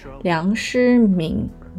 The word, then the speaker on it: 梁师闵: 梁诗这个字念敏，梁诗敏如听蜜雪。